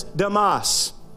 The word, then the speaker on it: Demas: It's the same phrase that was used of a man properly pronounced as Damas. That's the literal translation of his name. Probably safer for me to call him Demas.